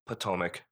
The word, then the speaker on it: Potomac: I feel you're putting the wrong emphasis on the wrong syllable. – Oh. – Yeah. It sounds like Potomac and cheese.